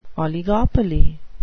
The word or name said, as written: oligopoly